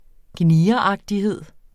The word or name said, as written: gnieragtighed